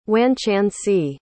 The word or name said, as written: wanchancy